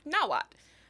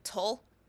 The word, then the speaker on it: Nahuatl: Um, so that's spelled N A H U A T L. So the TL that you see appear at a lot of at the end of a lot of Aztec words, mm-hmm. – your white professor probably pronounced it "tull." Mm-hmm. You do not pronounce it that way. That is a uh, like um it's it's actually it's a it's a unique phoneme of its own